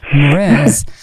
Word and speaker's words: Mraz: Ja, Mraz.